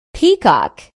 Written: peacock

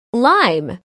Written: lime

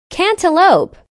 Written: cantaloupe

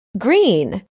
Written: green

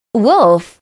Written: wolf

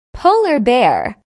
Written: polar bear

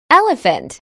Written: elephant

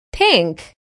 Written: pink

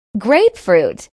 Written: grapefruit

